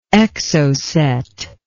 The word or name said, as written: Exocet